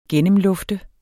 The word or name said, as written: gennemlufte